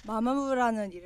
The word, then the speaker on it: Mamamoo: Mamamoo라는 이름을 할 때 약간 긴장을 하신대요. 그 발음이 맞는지 마마무가 맞는지 편하게 해달라고. – Just say comfortably, Mamamoo is fine.